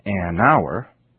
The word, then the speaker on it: hour: Also notice that words spelled with the letter H in the beginning, such as our, H-O-U-R, also use the article an, because the H isn't pronounced. So we say an hour,